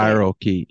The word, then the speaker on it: spirochete: Is it spirochete? How do you say it? – Spirochete. Yeah. – Spirochete.